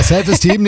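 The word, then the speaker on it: Self Esteem: The Offspring, Self-Eastern. – Ja, die Aussprache ist ein bisschen... – Steam, he's Steam. – Ja. Ja, ja, ist richtig. – Ja, Self-Esteem nehmen wir auch.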